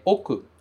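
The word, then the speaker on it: oku (奥): A pronúncia deste kanji é oku.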